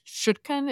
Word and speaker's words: Chutkan: Judge Tanya Chutkan, is that how you say her name? Mm-hmm.